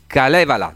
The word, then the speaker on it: Kalevala: Ok, Dario Caradente, Daniele Zoncheddu e Simone Casula, i Kalevala, giusto? – Giusto. – L'accento è, sbaglio sempre gli accenti, però stavolta va andata bene. È praticamente la formazione che prevede sei elementi, stasera ci sono in tre, ma molto rappresentativi.